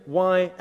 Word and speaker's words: Yahweh: No one knows how it was pronounced. But later on in Jewish history, when they started putting vowels in as well, it was written YAHWEH and pronounced Yahweh.